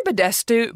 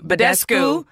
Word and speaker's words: Badescu: I say Badescu. – Badescu.